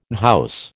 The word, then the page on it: unhouse: American English